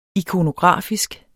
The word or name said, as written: ikonografisk